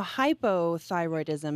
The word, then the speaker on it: hypothyroidism: Now, hypothyroidism means your thyroid is, there's not enough of it, basically.